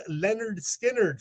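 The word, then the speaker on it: Lynyrd Skynyrd: Coming at number 2, that amazing debut release, pronounced Leonard Skinner from those Southern rockers from Florida,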